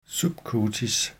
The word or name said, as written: subcutis